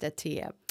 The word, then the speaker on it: Attia: I think it's pronounced Attia, but that's all right.